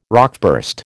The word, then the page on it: rockburst: American English